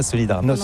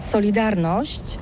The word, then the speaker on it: Solidarność: Alors solidarité, en français on dit Solidarnosc, mais ici à Gdansk on ne prononce pas Solidarnosc. – On prononce Solidarnosc.